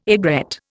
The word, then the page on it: aigrette: Indian